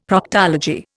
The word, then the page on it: Proctology: Indian